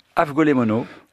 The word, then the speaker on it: avgolemono: Et donc, d'un coup, il a fait un porc au céleri avec des lanettes et avec une sauce, j'espère de bien le prononcer, avgolome mono. Comment vous dites-vous Afgolé mono.